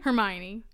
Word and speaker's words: Hermione: Hermione.